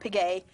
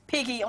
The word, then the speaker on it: Pegues: You know, my name's kind of hard, Pegues. – Sure. – Some people say Piggy. I'm like, oh, no. That's not it, you know.